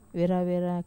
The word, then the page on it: vera-vera: Wamesa